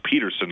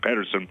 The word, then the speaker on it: Pettersson: Elias Pettersson, and again, Jack Michaels has gone, and Elias Pettersson has told them it's pronounced Pettersson, not Pettersson.